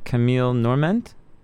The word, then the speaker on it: Camille Norment: And some of them, like, I guess if I'm pronouncing her name right, Camille Norment? – Right.